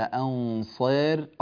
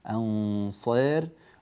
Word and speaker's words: أنصار: في قوله تعالى وما للظالمين من أنصار، ننبه هنا إلى قوله تعالى أنصار، الصاد مقللة بسبب أن جاء بعدها ألف وبعد الألف راء متطرفة مكسورة أنصار،